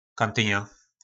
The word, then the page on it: kantyna: po našimu